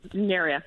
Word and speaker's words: Nuria: I rhyme it with area. – Okay. – So, Nuria.